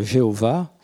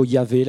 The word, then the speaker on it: Yahvé: Petite parenthèse, on peut traduire Yahvé par Jéhovah. Euh, mais c'est une mauvaise transcription. Euh, les, tous les, comment dit, les linguistes nous disent que c'est plutôt Yahvé la meilleure prononciation,